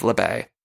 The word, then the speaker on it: La Baie: I'm not interested in learning the correct way to say it, but I believe it is LeBay, because some people did point me out to the official Instagram account of LeBay, Naomi's store, and it does say the saying on the logo, and then also on the Instagram account, it says like LeBay. It tells you how to pronounce it.